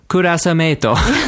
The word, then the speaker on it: クラスメート: クラスメート. Just the pronunciation changes. クラスメート. – Mm-hmm. – Because Japanese doesn't have the same sounds as English has, right? – Yes.